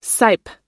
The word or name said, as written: sipe